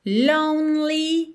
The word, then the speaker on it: lonely: Então nós temos done, you're, lonely. Esse E no meio da palavra lonely não é pronunciado, então fica lonely, tá? Do N você já vai direto pro L.